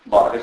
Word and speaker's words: Borges: Morto un 15 anni fa, Borges, scritto Borges, si pronuncia come lì spagnolo. Borges,